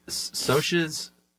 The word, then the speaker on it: Socs: He was kind enough to put socias, the pronunciation, because it's spelled S-O-C-S. – S-O-C-S.